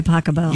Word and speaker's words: Pachelbel: Canon D by Pasquale. – By Pachelbel. – You pronounce it Pasquale? – No.